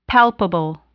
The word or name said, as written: palpable